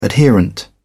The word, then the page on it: adherent: British English